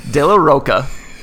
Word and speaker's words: De La Rocha: The song Just, which has Zach De La Rocha and Pharrell. – I like that you mispronounce both of their names. – I don't know. – Music podcast ever. – De La Rocha.